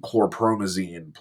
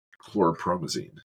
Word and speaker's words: chlorpromazine: Valium and chlorazepam? – Chlorpromazine. – Oh, yeah. Chlorpromazine.